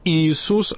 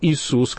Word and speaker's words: Иисус: Основные претензии были, это значит, что надо креститься не двумя перстами, а тремя перстами, что надо произносить имя Иисус, а не Иисус, как это, значит, было в старопечатных книгах.